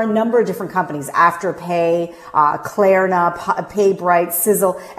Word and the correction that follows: Sezzle: and you'll Sezzle, I think it's pronounced.